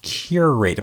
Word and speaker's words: curate: When you curate, I've said it a lot, I guess. Curate. Am I saying it right? Curate. – Curate.